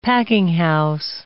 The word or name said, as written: packinghouse